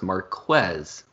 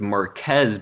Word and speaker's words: Marquez: his name is pronounced Marquez, which, as I mentioned earlier, is totally fine. But he said no one should have assumed it was Marquez because their name, or because his name has no K in it.